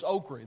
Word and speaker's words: okra: I know it's spelled okra, but it's okra. There's an EY in there somewhere down south.